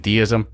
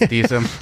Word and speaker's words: Deisim: Yeah, we've been saying Deism. It's Deisim.